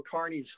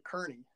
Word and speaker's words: Carney: Well, it's kind of like General Carney's family. They pronounce it Carney, like a circus Carney. – Yes. – And everybody else pronounces it Kearney.